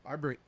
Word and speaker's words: Arbery: Arbery. Am I saying it right? Yeah, right. I said it right.